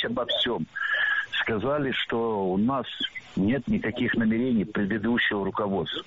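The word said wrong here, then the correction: намерение: Намерения – это неверно. Правильно – намерений. Ударение в этом слове ставим на второй слог и не меняем это ударение при изменении слова.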